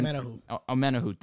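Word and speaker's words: Omenihu: Charge Omenu from the edge that I Omenahu. – thank you.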